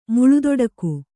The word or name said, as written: ಮುಳುದೊಡಕು